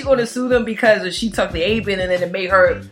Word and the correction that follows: Ambien: We actually pronounced it as Ambien. – Oh, it's Ambien.